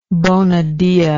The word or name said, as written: bonadea